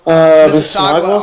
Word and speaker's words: Mississauga: Sim Bular is from, I really hope I say this right, Mississauga? – Mississauga, Ontario.